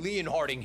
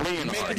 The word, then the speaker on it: Leonhard: There are a lot of names that are not pronounced the way they are spelled, including Jim Leonhard. – Yes, Jets Jim Leonhard.